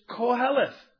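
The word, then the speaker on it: Koheleth (קהלת): The Hebrew word, and by the way, I made a point of asking two Orthodox Hebrews to make sure I was pronouncing this right. The Hebrew is Koheleth.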